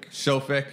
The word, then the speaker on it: SOFIC: SOFIC.